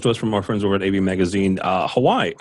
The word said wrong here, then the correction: Huawei: Hawaii, Huawei.